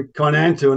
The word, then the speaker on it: Kainantu: And Kainantu, is that the right pronunciation? – That's correct, yep. – Right. – That's where the name comes from. Obviously, the abbreviation, alphanumeric abbreviation for Kainantu, and it seems to have really stuck.